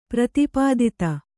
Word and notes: ಪ್ರತಿಪಾದಿತ: kannada

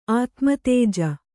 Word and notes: ಆತ್ಮತೇಜ: ātmatēja

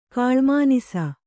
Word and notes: ಕಾಳ್ಮಾನಿಸ: kannada

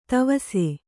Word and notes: ತಿವಿಸು: Kannada

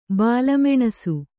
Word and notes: ಬಾಲಮೆಣಸು: bāla meṇasu

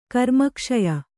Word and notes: ಕರ್ಮಕ್ಷಯ: karmakṣaya

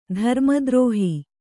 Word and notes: ಧರ್ಮದ್ರೋಹ: dharma drōha

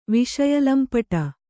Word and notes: ವಿಷಯಲಂಪಟ: kannada; viṣa lampaṭa

